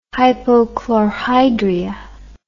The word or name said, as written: hypochlorhydria